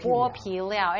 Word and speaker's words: 剝皮寮: In Mandarin, the word meaning to peel is pronounced bo and so, over time, Bo Pilao Street became the common name.